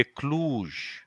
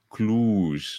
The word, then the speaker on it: Cluj: Ne pupă de la Cluj. Bă, așa nu, ceva. Nu ai pronunțat bine, e Cluj. Cluj, da, da, da, Cluj. E, se scrie de fapt cu doi de O, e Cluj.